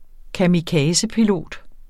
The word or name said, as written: kamikazepilot